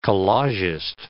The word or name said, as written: collagist